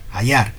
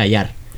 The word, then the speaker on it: Addar: Es Adar con dos Ds que se pronuncia Ayar.